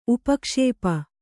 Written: ಉಪಕ್ಷೇಪ

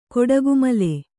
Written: ಕೊಡಗುಮಲೆ

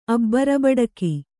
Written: ಅಬ್ಬರಬಡಕಿ

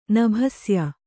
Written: ನಭಸ್ಯ

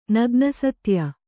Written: ನಗ್ನಸತ್ಯ

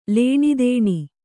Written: ಲೇಣೆದೇಣೆ

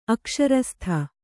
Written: ಅಕ್ಷರಸ್ಥ

